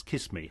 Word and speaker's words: chisme: And the group name that he chose for his particular bugs, he spelled C-H-I-S-M-E. – Which, of course, you pronounce kiss me.